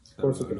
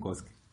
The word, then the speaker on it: Kosuke: Kosuke. Kosu, o Kousuke. Kosuke. – Kosuke. Lo, lo, pronuncian como Kosuke.